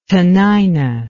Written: tanaina

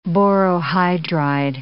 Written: borohydride

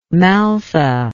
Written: maltha

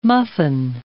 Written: muffin

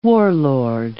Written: warlord

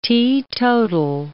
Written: teetotal

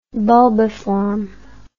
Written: bulbiform

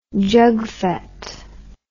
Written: jugfet

